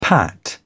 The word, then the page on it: pat: British English